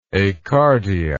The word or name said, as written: acardia